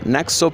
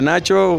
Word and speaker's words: Naxo: Sí, claro, los invito a todos primero que me sigan en mis redes sociales como Naxo, doble, eh, N-A-X-O, Naxo Piloto. Pero yo he estado pronunciando Nacho, ¿está mal? No, es en vasco. Se pronuncia Nacho o, Naxo. O sea, no, hay mucha, mucha, mucha diferencia.